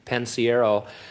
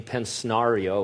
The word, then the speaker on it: Pensiero: I said, it's really simple, it's Pensiero. I said, just let's do it phonetically, and I had him write it down phonetically, Pensiero and stuff, and then he got up and introduced me as Gene Pensnario or something.